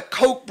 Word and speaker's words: Koch: He was owned by the Koch brothers and Mark Short.